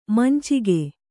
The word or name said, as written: ಮಂಚಕ